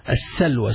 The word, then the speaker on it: السلوى: السلوى is called السلوى. سين لام واو يا مقصورة which is pronounced as an alif.